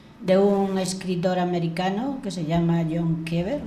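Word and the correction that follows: Cheever: John Chever.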